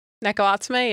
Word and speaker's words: Neko Atsume: Because the thing that people like now is like, what's Neko Atsume? – Neko Atsume, yeah.